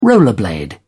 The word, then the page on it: rollerblade: British English